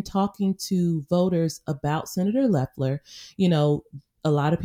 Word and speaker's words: Loeffler: And the other indication is people still aren't pronouncing her name correctly. You know, she's Loeffler and Loeffler and everything. So once people start pronouncing her name Loeffler on a consistent basis, she will have another indication that she has arrived, you know.